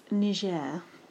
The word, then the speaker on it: Niger: Also, it's pronounced Niger.